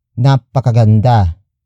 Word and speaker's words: napakaganda: Word for word, it's napakaganda ni Pia.